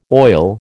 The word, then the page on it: oil: American English